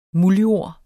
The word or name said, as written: MULDJORDS